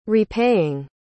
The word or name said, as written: repaying